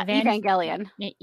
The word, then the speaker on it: Evangelion: Yeah, Evangelion.